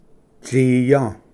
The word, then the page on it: ᏥᏯ: tsiya